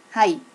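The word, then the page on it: hai: native Japanese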